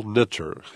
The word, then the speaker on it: Knitter: Paul Nitter, his name is spelled K-N-I-T-T-E-R, from Union Theological Seminary, wrote a book that's had quite a bit of press nationally and even internationally called Buddhist.